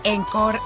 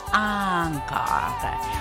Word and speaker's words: encore: Encore, acuérdense que el encore o el encore, como decimos nosotros, no se dice encore en francés, encore.